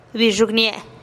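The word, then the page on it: bixugnie-e: Teotitlán del Valle Zapotec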